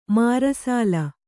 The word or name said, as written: ಮರೆಸಲ್